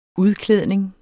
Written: udklædning

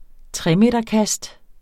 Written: tremeterkast

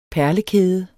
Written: perlekæde

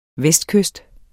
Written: vestkyst

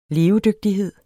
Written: levedygtighed

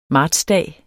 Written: martsdag

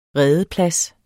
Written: redeplads

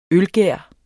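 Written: ØLGÆR

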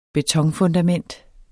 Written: BETONFUNDAMENT

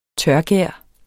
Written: tørgær

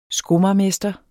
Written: skomagermester

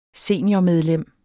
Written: seniormedlem

